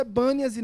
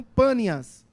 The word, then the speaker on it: Bânias: porque os árabes têm uma, uma dificuldade de pronúncia em relação à letra P, e por isso é Bânias e não Pânias.